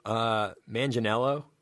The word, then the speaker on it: Manganiello: And Joe Manganiello, is that how you say it? – Yeah.